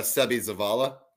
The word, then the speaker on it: Zavala: Sebi Zavala. So that takes our free agency. – Nice job with pronouncing the name. – Yeah, Sebi Zavala. – That's a tough one.